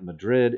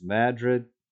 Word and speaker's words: Madrid: It's not Madrid, it's Madrid. Anyway.